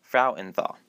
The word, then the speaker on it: Frauenthal: In order to approve the name change, she insisted that everyone at the theater and foundation learn to properly say the last name correctly, Frauenthal. Say it with me now, Frauenthal.